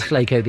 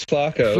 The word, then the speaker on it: Flaco: If I pronounced it right, it is Flaco the owl. – No, actually, it's Flaco.